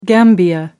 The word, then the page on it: gambia: American English